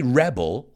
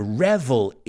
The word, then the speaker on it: revel: And I didn't say rebel like rebelde, I said it with a V. To revel in is disfrutar, deleitarse, otra que me cuesta pronunciar, gozar.